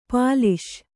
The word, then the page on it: ಪಾಲಿಷ್: Kannada